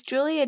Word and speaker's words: Giulia: I've had, so it's Julia Giannini McGoran, but I've had Grulia McGosnan before.